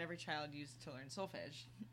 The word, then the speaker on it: solfège: It's solfège.